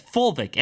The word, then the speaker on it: fulvic: Look up, I can't pronounce this, fulvic acid. Yes, fulvic, F-U-L-V-I-C acid.